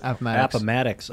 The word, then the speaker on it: Appomattox: Oh, when the surrender was happening at Appomattox, how do you pronounce it? – Appomattox. – Appomattox. Man, way off.